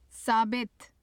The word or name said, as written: sabit